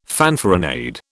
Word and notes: fanfaronade: British English

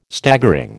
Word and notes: Staggering: American English

